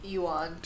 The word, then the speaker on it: yuan: And it was apparently the plan was to collect 100,000 yen, or whatever, yuan. – Yuan. – To pay off. Juan, or whatever the fuck it's called. – I looked up the pronunciation.